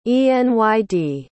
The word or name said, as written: enyd